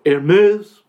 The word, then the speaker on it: Hermès: Birkin, bags, originally inspired by British actress Jane Birkin and designed by Hermès of Paris. Ah. – French pronunciation is flawless.